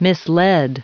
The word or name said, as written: misled